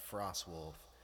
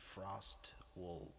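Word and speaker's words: Frostwulv: My name's Frostwolf, and the wolf is spelled W-U-L-V, Frostwolf, oh yeah, PS4, bitches.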